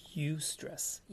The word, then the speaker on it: eustress: And there is such a thing as good stress, and it's pronounced eustress, eustress, right?